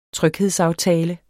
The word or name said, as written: tryghedsaftale